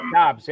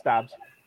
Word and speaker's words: Dobbs: I don't know how to pronounce his last name, but Dobbs. Yeah, you got it. Dobbs.